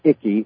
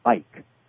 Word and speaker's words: Icke: And for Looney Tunes out there, it's not Icky, it's Ike. That's how you pronounce I-C-K-E. It's pronounced Ike.